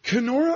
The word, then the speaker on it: Kenora: William White grew up in, I'm going to pronounce it, Kenora, Ontario. The distance from, yes. – Am I pronouncing that right? – Yes, absolutely. – Kenora, Ontario is 1,200 miles from Toronto.